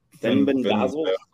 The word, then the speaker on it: Fenbendazole: He says, tell to look for Fenbendazole. I can't pronounce it. – Fenbendazole.